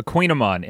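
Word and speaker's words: Kouign-amann: Any of our listeners, it's kind of hard to find these, a Queen Amon, and it's spelled Kouign-Amon, AMANN.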